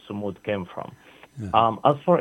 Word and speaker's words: Sumud: And for those who are listening to us, Samud is spelled S-U-M-U-D. Mm-hmm. S-U-M-U-D, Samud.